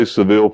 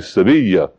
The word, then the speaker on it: Sevilla: I say Seville for you because thanks to Cadillac, the only thing you know about how to pronounce it is Seville, but it's actually Sevilla is the way you say the name of the city.